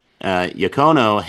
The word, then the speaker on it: Tonino Iacono: And 11 of them are all from one carver, the Italian carver, Iacono, if I'm saying his name right. – Tonino Iacono. Yeah.